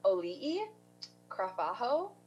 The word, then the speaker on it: Auli'i Cravalho: I also looked up how to pronounce her name because I didn't want to mess it up. It's Auli'i Cravalho, who was the voice of Moana.